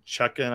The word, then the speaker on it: Chutkan: And that was that on the initial look at the decision by the judge today, Judge Chutkan, I think you say her name. Is that right? – Yes, there she is.